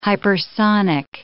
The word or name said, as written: hypersonic